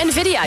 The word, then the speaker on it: Nvidia: Volgens mij heet die chipmaker Nvidia, zeg ik dat goed? – Oh, Nvidia, ja, precies.